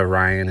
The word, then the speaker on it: Orion: You know that Orion is pronounced O-Ri-on.